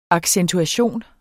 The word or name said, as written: accentuation